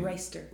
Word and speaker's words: Reister: Reister.